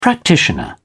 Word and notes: practitioner: British English

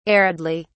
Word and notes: aridly: British English

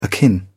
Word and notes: akin: British English

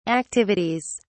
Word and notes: activities: British English

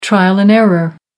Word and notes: trial-and-error: American English

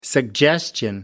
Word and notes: suggestion: American English

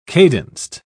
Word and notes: cadenced: British English

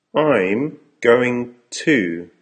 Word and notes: I'm going to...: American English

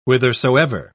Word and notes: whithersoever: British English

